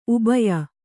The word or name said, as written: ಉಬಯ